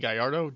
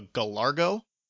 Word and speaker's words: Gallardo: Soulja Boy could not pronounce Gallardo, which it's a Lamborghini Gallardo, G-A-L-L-A-R-D-O, it's Italian. And throughout his video challenging Bow Wow, he kept calling it a Gallargo with a hard G in there, which doesn't exist in the actual word.